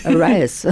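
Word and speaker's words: arayes: Arayas. My children always say I mispronounce it. – Yes. – Arayas. How do you say it? – I say arrays. – I also say arrays.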